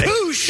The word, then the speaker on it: Puech: Nicholas Pooch, I guess is how you say Pooch. – His name. P-U-E-C-H.